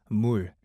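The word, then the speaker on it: mul (물): But here's my favorite word, and that is mul, M-O-O-L, or it's spelled M-U-L.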